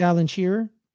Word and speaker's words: Alan Shearer: Yeah, with probably with Alan Shearer. – Yeah. – Nice. Yeah, I said the name right. – You did.